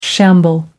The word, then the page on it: shamble: American English